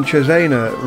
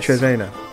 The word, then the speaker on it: Cesena: Today we're in Cesena, Richard. Is that right? Sorry. – Yes. – Cesena.